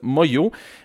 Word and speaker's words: Manglo: En fait, ça s'écrit Manglo, M-A-N-G-L-O, mais ça se prononce Moyo, avec la chanson « Écoutez tous mon histoire ».